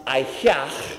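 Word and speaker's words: Ehyeh: It comes from the same root as I am, which is pronounced Ayah in Hebrew.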